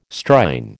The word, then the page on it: Strine: American English